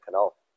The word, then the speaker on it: Knull: Yeah, normally K's are non-silent, but it's a German pronunciation, and in Germany, they like to pronounce all the letters in every word, and so with the K-N-U-L-L, that's the way to say it, is the Canal.